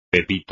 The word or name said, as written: pepito